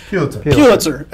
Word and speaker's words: Pulitzer: How do you pronounce that? – Pulitzer.